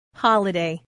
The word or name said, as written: holiday